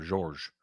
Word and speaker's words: Georges: Pronounced in French manner, Georges. French is very common second language in Lebanon.